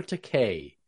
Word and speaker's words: Takei: Is it Takei or Takei? How is it pronounced? – Takei. – Takei.